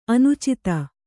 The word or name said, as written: ಅನುಚಿತೆ